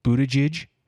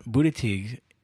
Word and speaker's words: Buttigieg: Of the new people, and even this, I think his name's pronounced Buttigieg.